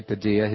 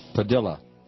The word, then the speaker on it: Padilla: It's not Jose Padilla. His family has requested that his name be pronounced Padilla. So be it. Jose Padilla.